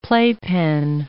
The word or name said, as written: playpen